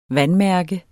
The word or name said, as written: vandmærke